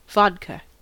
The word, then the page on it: vodka: American English